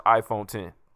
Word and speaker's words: iPhone X: Which really stands for iPhone 10. – I think it's X because why would they just skip 9? – Yeah. No. It's pronounced iPhone 10.